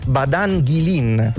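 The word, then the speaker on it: Badain Jaran: che si scrive Badain Yaran, ma in realtà abbiamo controllato, si legge Badan Gilin.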